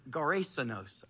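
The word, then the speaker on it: Gerasenos (Γερασηνῶν): Jesus and his disciples go across a lake to the region of Gerasenos. I know it doesn't look like that, but that's how it's pronounced in the Greek. Gerasenos. And when Jesus got out of the boat, a man with an impure spirit, a demon, comes from the tombs to meet him.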